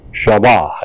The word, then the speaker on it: shaba: Shaba, S-H-A-B-A, pronounced Shaw, S-H-A-H, Ba, B-A-H.